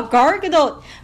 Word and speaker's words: Gal Gadot: 但是大家知道高尔加朵的英文名字怎么念吗？叫 Gargadot，Gargadot，是不是听上去和女神完全不搭边？所以我觉得能把 Gargadot 翻成盖尔加朵真的很不容易。